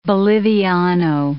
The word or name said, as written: boliviano